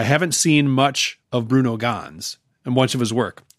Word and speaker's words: Ganz: I think Ganz is how you pronounce it. – I think it's probably right.